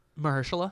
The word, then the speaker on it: Mahershala: Mahershala? – Yeah, Mahershala Ali.